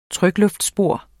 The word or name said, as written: trykluftbor